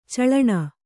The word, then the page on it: ಚಾಳಣಿ: cāḷaṇi